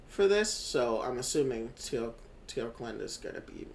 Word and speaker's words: Talokan: It is uh, Talokan. Wait, let me see. Yeah, that's about right. – Yeah, Talokan.